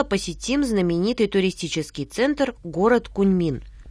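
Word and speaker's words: Кунмин (昆明): Кун произносится в первом тоне, а Мин читается во втором тоне. Повторяйте за мной. Кунмин.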